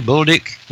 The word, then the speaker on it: Bulduc: B-U-L-D-U-C, Bulduc.